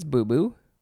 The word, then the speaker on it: boubou: I think it's boo-boo. B-O-U-B-O-U.